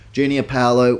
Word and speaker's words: Junior Paulo: As for my Tough Stuff Award, the first one given out, I have gone jo- Junior Paolo. I think that's how he wants it to be pronounced. Junior Paolo was outstanding, ran for over 200 meters, led from the front in every respect up against an undefeated Panthers side.